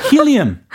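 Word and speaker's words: helium: 헬륨. – 힐리엄. – 힐리엄. – 아 이거 발음 너무 달라서.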